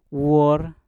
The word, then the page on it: wor: mpur